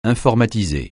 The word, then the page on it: informatiser: francais